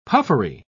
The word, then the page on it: puffery: British English